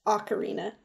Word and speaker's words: Ocarina: Let me state for the record, I have always and will always want to say Ocarina of Time, but I have since learned that that is not correct. Ocarina is the correct pronunciation, and whatever.